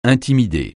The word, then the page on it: intimider: francais